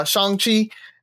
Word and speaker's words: Shang-Chi: They actually explain how to say his name inside of the movie. So it is uh, Shang-Chi, and uh, it is freaking awesome.